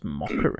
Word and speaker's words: Macher: It is Stu Macca. – I don't know his last name. How do you spell Macca? – M-A-C-H-E-R. – H. Yeah, right, Macca. I think it's pronounced Mocker, isn't it? Right.